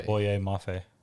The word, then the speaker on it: Boye Mafe: I could be wrong, but I think it could be Boye. – Boye Mafe.